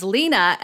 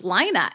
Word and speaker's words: Lina: the character's name in her head when she was reading was Lena, and then the audiobook, it's pronounced Lina, and it was like a barrier for entry to her.